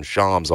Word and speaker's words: Shams: And is willing to, it sounds like, from Shams, whatever y'all are calling Shams him in terms of pronunciation today, we call him Shams on this show.